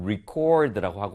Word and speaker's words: record: 그래서 이렇게 같은 철자 RECORD 동일한데도 불구하고 어떻냐면, 이 품사에 따라서 동사일 때 명사일 때 발음이 다릅니다. 동사일 때는 record 뒤에 강세가 가고요. 그리고 명사일 때는 앞에 record라는 발음이 되는 것이죠. 그래서